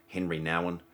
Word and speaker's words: Henri Nouwen: Uh, I actually read a book recently um, by a bloke called Henri Nouwen, pronounced in Australian, Henry Nowen, And uh, anyway, he was writing on uh, Kairos time and Chronos time.